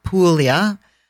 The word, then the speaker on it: Puglia: So, Polignano a Mare, it's a hard word to say, just like Puglia. The G is uh, not pronounced.